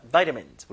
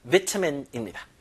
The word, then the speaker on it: vitamins: be rich in vitamins 발음할 때 비타민이 아니라 vitamins 물론 영국 발음은 vitamin입니다.